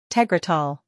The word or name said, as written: Tegretol